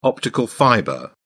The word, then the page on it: optical fibre: British English